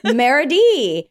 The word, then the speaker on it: Merity: Next up, Merity. Rhymes with charity.